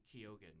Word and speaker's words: Keoghan: I kept saying Keegan. but I think he's Irish, so it makes sense that there's an O in there.